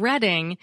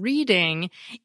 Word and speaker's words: Reading: Second, I sincerely apologize for the pickle I put all of you in by mispronouncing the name of the University of Reading as the University of Reading in a show about not correcting people who mispronounce things.